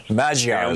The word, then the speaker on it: Magyar: Yeah, Magyar is how it's pronounced.